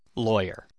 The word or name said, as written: lawyer